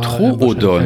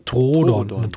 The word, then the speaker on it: Troodon: Also im Englischen würde man sagen Trudon, aber Troodon. – Heißt es Troodon. Troodon, genau, würde ich auch sagen.